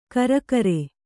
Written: ಕರಿಕಾರೆ

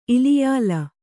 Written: ಇಲಿಯಾಲ

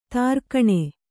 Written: ದೊರೆಗಾಣ್

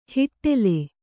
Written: ಹೊಟೇಲು

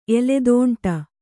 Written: ಎಲೆದೋಂಟ

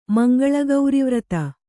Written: ಮಂಗಳಗೌರಿವ್ರತ

